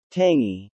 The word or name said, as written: tangy